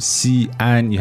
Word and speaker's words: 西安: Right, X-I-A-N can be a single syllable in pinyin. So if you want that to be two syllables, 西安, you have to put the apostrophe in the middle.